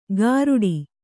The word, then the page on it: ಗಾರುಡಿ: gāruḍi